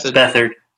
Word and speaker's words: Beathard: I had third string QB, CJ Beathard, if I'm saying that right. – Bethard. – Bethard. Thank you guys.